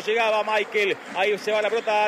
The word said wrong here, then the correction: Miquel: ¿Cómo le decimos? ¿Michael o Miquel? Nicolás Álvarez, usted que es especialista en nombre. Se escribe Miquel. ¿Cómo le digo? – Decirle Miquel. – Entonces le digo a Miquel, usted me enseña y yo aprendo.